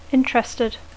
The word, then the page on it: interested: English